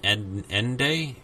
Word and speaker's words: Ende: This was based on a novel by Michael Ende, is how you say E-N-D-E, which I have the book actually, and it follows it fairly closely.